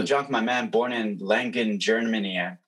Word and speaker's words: Langen: Correct me if I'm saying it wrong. – No, that's good. You're the first person that has said that kind of on point. So word up. Langen. How Langen. We say it in Germany.